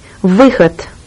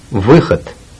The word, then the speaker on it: Выход: Выход. – Выход. – Выход. The exit. This letter, which resembles a 61, is pronounced like an I in visual.